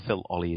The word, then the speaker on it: Phil Olley: And Phil Olley is spelled P-H-I-L-O-L-L-E-Y.